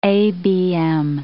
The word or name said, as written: ABM